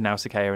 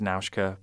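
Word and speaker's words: Nausicaä: I've said Nausicaa in the past because that's what I was taught at school, the Greek myth. But yes, in the film, it's Nauska because that's how the Japanese people say it.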